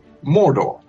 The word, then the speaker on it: Mordor: And I said, well, young characters like the hobbits will say, as we do, Mordor.